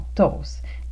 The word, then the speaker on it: toast: Prononcer toast, on ne prononce pas le T final, donc toast, des toasts.